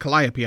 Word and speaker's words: Calliope: There's even the circus instrument of the Calipea, is that how you pronounce it? – Calliope, I believe, is what it's called. Calliope, yes, of course that is.